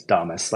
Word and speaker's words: Damus: No, it's Domus, like Nostradamus.